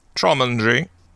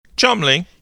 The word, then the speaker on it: Cholmondeley: They've been around forever, and their last name is spelled, like, Cholmondeley. Guess how it's pronounced. – Cholmondeley. – Cholmondeley is, like, what it looks like phonetically. – Cholmy. – You're very close. It's fucking Chumley.